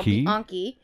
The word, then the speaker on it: Bianchi: Awkward Key? No, Bianchi.